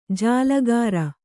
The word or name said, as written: ಜಲಗಾರ